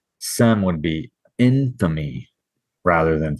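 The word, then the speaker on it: sem (שם): So if the shin is accented on the left, it's going to be pronounced without the H. And so it would be sem.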